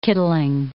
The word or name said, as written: kittling